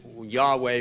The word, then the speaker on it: Yahweh: I appeared to Abraham, to Isaac, and to Jacob as God Almighty, but my name, Eternal," or the YHVH, we pronounce it Yahweh, but we don't know what the vowels are.